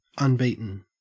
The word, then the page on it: unbeaten: Australian English